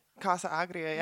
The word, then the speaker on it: Casa Agria: Casa Agria,